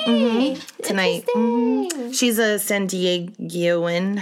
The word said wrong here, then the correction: San Diegan: How do you say? – San Diegan. – San Diegan. Yeah.